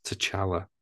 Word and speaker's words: T'Challa: The people of Wakanda fight to protect their home from intervening world powers as they mourn the death of King T'Challa.